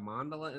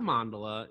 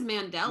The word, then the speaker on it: mandala: I thought it was Mandela. – Maybe it's Mandala. I'm pretty sure it's not Mandela, because that's the guy.